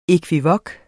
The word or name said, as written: ekvivok